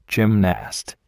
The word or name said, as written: gymnast